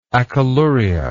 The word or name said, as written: acholuric